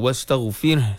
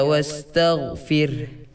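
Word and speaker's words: wastaghfir (واستغفر): Ha tak bunyi tu, wastaghfir sekali lagi. – Fasabbih bihamdi rabbika wastaghfir.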